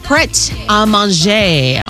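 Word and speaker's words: Prêt A Manger: Wear it to a Prêt à manger next Wednesday.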